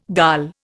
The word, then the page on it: dal: Indian